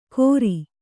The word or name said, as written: ಕೊರಿ